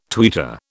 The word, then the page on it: tweeter: British English